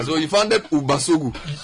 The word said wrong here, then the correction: Ubisogu: No, help me pronounce that thing. – It's Ubisogu.